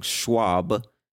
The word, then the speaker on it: Schwab: Our friend Jim is talking about Charles M. Schwab, or Schwab, I said it wrong, Schwab, S-C-H-W-A-B, Schwab.